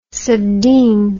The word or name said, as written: subdean